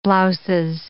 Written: blouses

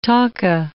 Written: taka

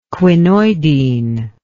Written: quinoidine

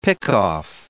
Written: pickoff

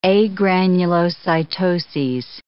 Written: agranulocytoses

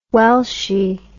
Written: welshy

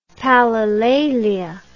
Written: palilalia

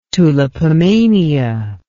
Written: tulipomania

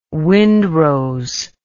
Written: wind rose

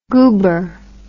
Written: guber

